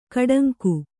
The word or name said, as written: ಗೂಢಾಂಗ